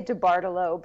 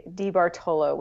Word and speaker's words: DiBartolo: I usually say DiBartolo, but DiBartolo? – People who know Italian say DiBartolo, which I actually prefer, even though that's not how anyone in my family says it.